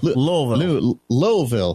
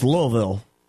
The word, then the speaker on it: Louisville: Louisville. – There we go. – They don't pronounce it as Louis. It's Louis, and it's Louisville.